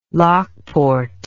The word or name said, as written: lockport